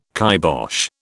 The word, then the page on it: kybosh: British English